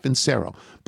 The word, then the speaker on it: Vincero: Vincero, V-I-N-C-E-R-O, the Italianate pronunciation, not Vincero, but Vincero.